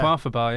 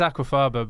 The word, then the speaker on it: aquafaba: You can use a quaffer bar, yeah. – Yeah. – Quaffer bar. – It's aquafaba, but I reckon it sounds nice if you pronounce it a quaffer bar.